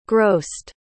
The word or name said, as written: grossed